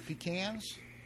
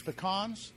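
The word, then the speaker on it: pecan: If you're from the south, it's pecans. If you're from up north, it's pecans.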